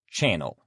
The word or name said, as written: channel